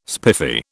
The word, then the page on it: spiffy: British English